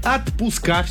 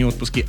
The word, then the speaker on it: отпуска: Далее, козыри, отпуска, все-таки не отпуски, отпуска, диспетчеры и повара.